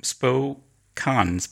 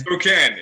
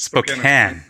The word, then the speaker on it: Spokane: Welcome joining us from Spokane. Oh, no, I'm getting it wrong again. Tell us, what's the correct pronunciation again? – Spokane. – It's Spokane. Spokane, Washington.